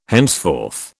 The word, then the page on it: henceforth: British English